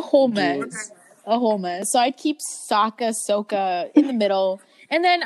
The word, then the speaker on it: Sokka: You mean Sokka? Am I saying his name right? – Yeah. No, in the M. Night Shyamalan movie, he says Sokka. Oh, you mean Sokka? – My bad, Sokka.